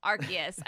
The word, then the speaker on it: Arceus: It's Arceus.